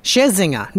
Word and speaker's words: Scherzinger: Like Zinger Burger. – I was trying to put too many... – too many syllables in there where there really wasn't calling for more syllables. – We'll go with Scherzinger. – Scherzinger. Just think Zinger Burger.